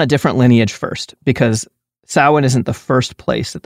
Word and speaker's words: Samhain: Instead of immediately tracing it to Samhain,